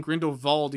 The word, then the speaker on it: Grindelwald: And though and like those like that, even saying Grindelwald, you say it with a V, like it's pronounced with a v.